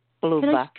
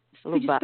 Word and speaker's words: Luba: Hi, this is Lupa speaking. – I'm sorry, what? Luba? – Luba. – Could you spell that? Luke? – Luba. L-U-B-A.